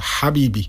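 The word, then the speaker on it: habibi (حبيبي): On je to habibi. Není to ha, ale tady skrko habibi.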